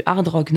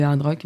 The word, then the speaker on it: hard rock: Mais effectivement, c'était un festival français, c'est ça, et c'était du rock, hein, du hard rock, non Oui, alors il y a du hard rock, comme on prononce à la française euh, sans prononcer le H.